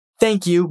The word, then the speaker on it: thank: When you say the TH in thank, stick your tongue out and use your voice.